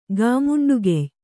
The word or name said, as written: ಗಾಮುಂಡುಗೆಯ್